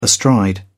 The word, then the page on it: astride: British English